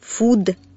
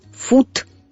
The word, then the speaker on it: food: Еда по-английски – «food». И очень важно четко произносить последний согласный звук «д». Иначе знаешь, что получится? – Что? – Если оглушать звук «д», то будет слышаться «т», и получится «фуд».